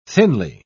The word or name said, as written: thinly